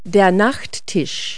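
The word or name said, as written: night table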